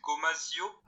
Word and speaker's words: gomasio: Euh, en France, c'est bizarre la prononciation, c'est gomasio. Ah, ça me dit quelque chose, ouais. Ça me dit quelque chose. – Bah, ça veut dire. Enfin, ça devrait se dire Gomachio, je pense.